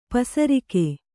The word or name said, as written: ಪಸರಿಕೆ